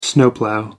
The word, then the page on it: snowplough: American English